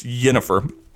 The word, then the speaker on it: Yennefer: Her name on the show was Yennefer. And for a while, I thought it was like the Nordic or the Yiddish Jennifer. But no, it's spelled with a Y. It's Yennefer.